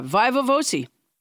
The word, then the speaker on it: viva voce: I did look up the pronunciation. Okay. Yeah, viva voce.